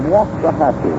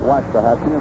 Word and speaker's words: Waxahachie: Richards comes from Waxahachie. He tells me that's the way to pronounce it down in Texas. We've been calling it Waxahachie, and we apologize now to every citizen of Waxahachie.